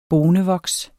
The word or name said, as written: bonevoks